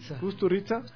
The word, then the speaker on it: Kusturica: Kusturica.